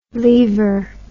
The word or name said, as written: leaver